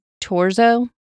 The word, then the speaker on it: Thurzo: Okay, and this name is T-H-U-R-Z-O, but when I listened to it, they said Torzo. – Uh-huh. – So I'm going to assume that's how it's pronounced. When I was reading it, was thinking Thurzo, but it's spelled T-H-U-R-Z-O.